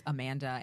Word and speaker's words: Amanda: Well, I'm just saying, like, this is how you say my name and it's Amanda and this is how Amanda says it.